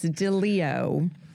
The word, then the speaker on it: Deleo: Yes, so Canon, it's Deleo, as in of the lion.